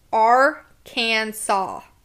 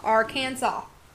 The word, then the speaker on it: Arkansas: It says it's Arkansas. – Arkansas. – Arkansas. I see, I'm going to say, I've been saying it wrong. It's called Arkansas. – Arkansas, not Arkansas.